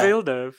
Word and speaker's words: Villeneuve: Villeneuve, isn't it? No. It's like the F1 driver. – Denis Villeneuve. – Yeah, Villeneuve, surely.